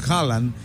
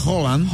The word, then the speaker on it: Haaland: Aparte de que lo, bueno, como lo pronunciamos nosotros, supuestamente no es la pronunciación correcta, que es que decimos Haaland, que Holland. Creo que es Haaland.